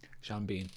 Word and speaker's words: Sean Bean: Is it Sean Bean or Sean Bean? – Sean Bean. – Are you sure? – Yes.